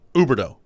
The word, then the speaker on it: Huberdeau: No, you just don't pronounce the H. It's Huberdeau.